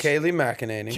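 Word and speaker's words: Kayleigh McEnany: Kaylee McEnany.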